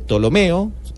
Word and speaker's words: Ptolomeo: Ahí los griegos que él lleva, un general, Ptolomeo, así se pronuncia, construye esta gran biblioteca y da la orden de que a todos los lugares donde haya libros los lleven hasta allá.